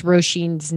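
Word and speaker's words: Róisín: And it's funny, I never knew how to pronounce Roisin's name. Did I say it right? – Yes, Irish name. Yep, Roisin.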